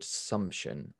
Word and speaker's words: Sumption: One of the interesting things that I found in the book, you talked about, you mentioned a quote by Lord Sumption, I think is how you correctly pronounce it, saying, I'm paraphrasing this quote, but it says, real freedom is lost when we surrender freedom to threats that are greatly exaggerated.